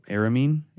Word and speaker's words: Aramean: From but you can pronounce it like Aramean from today, though. It's spelled different. – That's Arminian. – Right. – No, this is Aramean. It's spelled like A-R-A. Okay. So it was Ara.